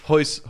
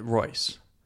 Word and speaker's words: Royce: Hoist, Hoist Gracie. – Yeah. Hoyler he, Gracie. He wasn't on there. It's uh, Royce. They oh. in Brazil, R's are pronounced as H's.